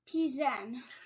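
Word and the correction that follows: Plzeň: Yeah, that's an L, not an I, believe it or not. They need to buy a vowel. P-L-Z-E-N. – Pilsen. – Yeah, it's actually pronounced Pilsen, it turned out.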